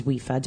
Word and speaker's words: WIFA: So if you get a chance, check out the Women in Fitness Association, which is WIFA, W-I-F-A is how that's pronounced.